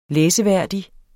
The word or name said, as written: læseværdig